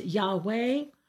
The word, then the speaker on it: Yahweh: Sometimes it's pronounced Yahweh, or in English, they made it Jehovah, and that's not exactly correct.